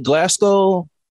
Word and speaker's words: Glasgow: Glasgow? – Chef's kiss.